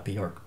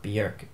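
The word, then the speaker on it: Björk: Bjork. That's how you say it. Damn it, not Bjork. Bjork. Get it right, people.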